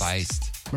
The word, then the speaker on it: Feist: Feist? It was Feist. – Feist.